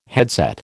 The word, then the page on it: headset: American English